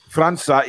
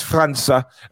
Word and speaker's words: França: Any news regarding Franco, the young South American player? Well, last we heard, that the, you know, the, França, yeah, please say it right. It's França.